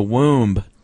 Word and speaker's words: womb: It starts in the womb. – You pronounce the B? – No, I just did that as a joke.